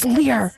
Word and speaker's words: Lyr: like, like it's Lyr. It's Lyr, not Liar.